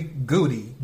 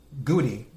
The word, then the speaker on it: Goudey: Goody, G-O-U-D-E-Y, Goody, gum card that has been authenticated as having a 9 out of 10 rating.